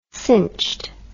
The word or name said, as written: cinched